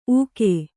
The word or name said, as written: ಉಘೇ